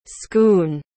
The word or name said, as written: schoon